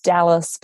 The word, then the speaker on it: Dahlas: And yes, my name's Dahlas, but it's spelt differently with an H, old family name.